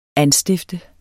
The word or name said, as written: anstifter